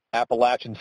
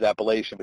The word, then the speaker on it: Appalachian: I'm from Pennsylvania, and up here, anytime we talk about that word, it's always Appalachian, but he told me I'd have a riot on my hands if I pronounce it that way.